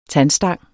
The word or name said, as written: tandstang